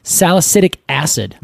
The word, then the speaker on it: salicylic acid: It's also been used for, its bark has a little bit of, I'm going to say this wrong because I always do, salicylic acid.